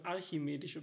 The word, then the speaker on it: archimedische: nämlich, dass ich in der Folge von dem ähm, ähm, also ich habe es falsch ausgesprochen. Ich habe gesagt das alchimedische Prinzip, aber es ist das archimedische Prinzip.